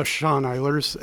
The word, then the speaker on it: Sean Eilers: Sean Eilers, S-E-A-N-E-I-L-E-R-S.